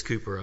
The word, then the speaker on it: Cowper: It's spelled Cowper. So if you see it, but it is Cowper.